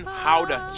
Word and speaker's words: Gouda: And the pride and joy of the nation, Gouda cheese. Yep, that's how you pronounce it, guys.